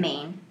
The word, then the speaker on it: Redmayne: Redmayne.